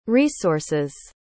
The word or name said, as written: resource's